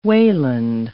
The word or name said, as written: Wayland